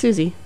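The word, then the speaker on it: Siouxsie: Yeah, it's pronounced Susie.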